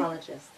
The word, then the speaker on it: apologist: She just got certified as an apologetist. Did I say that correctly? – Apologist. – Apologist. Yeah.